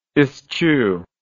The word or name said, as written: eschewer